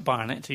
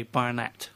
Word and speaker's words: Barnett: Now, this next one is a new single by Courtney Barnett, which is how you say her name. It's not Barnett, you say Barnett.